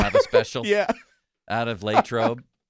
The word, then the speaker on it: Latrobe: You think they could fly out of Latrobe? – I like that you say it right. Mike DeFabo tomorrow will be proud. Latrobe, you said it.